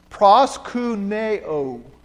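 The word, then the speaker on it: Proskuneo (προσκυνέω): The third word down is the way to pronounce that word. Proskuneo.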